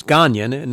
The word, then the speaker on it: Gagnon: It's Gagnon, not Gagnon, because it's G-A-G-N-O-N.